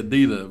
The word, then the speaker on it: Adidas: That's first time I ever heard somebody say Adidas, but he said it right though.